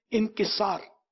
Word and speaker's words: انکسار: عام طور پہ انکسار ہی بولتے ہیں لیکن صحیح تلفظ جو ہے نا لفظ یہ انکسار، عجز و انکسار یا عاجزی و انکسار.